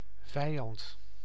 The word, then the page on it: vijand: /ˈvɛi̯ɑnt/